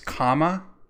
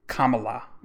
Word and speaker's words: Kamala: So speaking of Kamala or Kamala, or I think it's Kamala, because I put on Instagram, I just spelled comma and put la at the end. Kamala, that's how you say it.